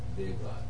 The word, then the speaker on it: Devraj: His last name is spelled D-E-V-R-A-J. How do you pronounce that? – Dave Raj. – Dave Raj. I almost can say that. Dominic, D-O-M-I-N-I-C, Dave Raj, D-E-V-R-A-J.